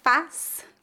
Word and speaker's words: faz: A palavra faz, com Z, é o verbo fazer conjugado na terceira pessoa do singular. A pronúncia dessa palavra é feita com A aberto.